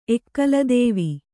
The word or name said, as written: ಎಕ್ಕಲದೇವಿ